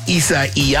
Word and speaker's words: Isaias: So you can say Isaias and pronounce the A-S on the end of... – Isaias. – Isaias. Or you can say Isaiah.